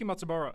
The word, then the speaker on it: Matsubara: That was Miki Matsuraba. Is that how you say it? Yeah, that Miki Matsubara. Sorry, Miki Matsubara.